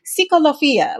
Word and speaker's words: Psicolofia: I have to like read the pronunciation, like Psicolofia. Like, I think I said that correctly. – No, you did.